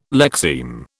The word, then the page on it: lexeme: British English